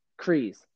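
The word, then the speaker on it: Kryze: Clan Kress? Is that how it... Say that again? – Krees.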